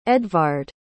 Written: edvard

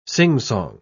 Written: singsong

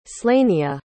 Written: slania

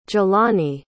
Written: Jelani

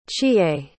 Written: chiye

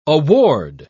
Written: award

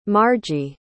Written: margy

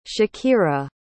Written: shakera

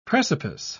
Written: precipice

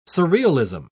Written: surrealism

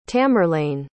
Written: tamerlane

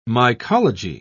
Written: mycology

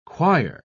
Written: quire

